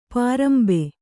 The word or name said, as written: ಪಾರಂಬೆ